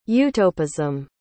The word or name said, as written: utopism